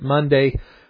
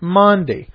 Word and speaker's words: Maundy: Well, why are we calling it Monday Thursday? But we weren't calling it Monday Thursday. We were calling it Maundy. And Maundy is spelled M-A-U-N-D-Y.